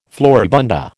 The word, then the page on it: floribunda: American English